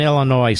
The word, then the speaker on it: Illinois: But, and by the way, why is the S in Illinois silent? – I don't know. It's a French thing.